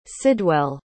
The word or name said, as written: sidwell